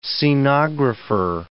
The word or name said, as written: scenographer